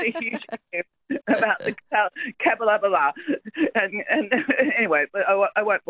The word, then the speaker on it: Capalaba: Because that's how, if you look at it, it's C-A-P-A-L-B-A, Capalaba.